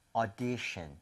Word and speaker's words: audition: 이거 발음하실 때도요, 오디션이 아니라 5에서 조금만 더 벌려주시면 돼요. 그래서 audition, 아하. – audition 조금만 벌려서 5보다는 차라리 어에 더 가까운 게 낫습니다.